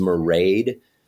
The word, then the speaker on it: Mairead: Her name is Mairead, which rhymes with parade.